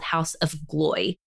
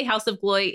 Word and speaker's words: Haus of Gloi: I say Haus of Gloi. It could be something totally different, but that's how I say it in my mind.